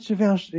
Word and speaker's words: Givenchy: But I'm not saying that as in, is it Givenchy? Yeah, it's Givenchy.